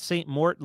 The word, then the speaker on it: Saint Mort: Uh, I am at Saint Mort literally everywhere. Uh, so that's spelled out S A I N T M O R T.